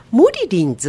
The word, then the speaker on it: 森林: そうですね、うん、こういう人もいるけどこういう人もいるっていう、うん、人はみんな違うっていうことですよね、うんうん、で泉企画さんなんですが、モリリンズっていう、はい、これは愛称なんですか。 – そうなんです。モリリンっていうのは実は森林と書いて、あ、モリリンと読ませていて、ひらがななんですよね。